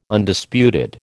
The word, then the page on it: Undisputed: American English